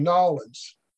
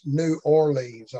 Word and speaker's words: New Orleans: He's down in Nolens. Y'all know where Nolens is, don't you? For you people up north, that's New Orleans, I think is the way y'all pronounce it. Yeah, that's Nolens.